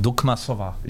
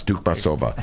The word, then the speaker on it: Dukmasova: Right, I believe the correct Russian pronunciation would be Dukmasova. – Yes, Dukmasova. – It's actually Dukmasova.